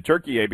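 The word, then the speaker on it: Türkiye: back, I think, in like a couple years ago, the Turkish government requested that the United Nations change the country's English designation to Turkey A, because that's actually how they pronounce it, and basically to distance themselves from the English word and the bird, of course.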